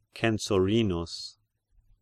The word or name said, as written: Censorinus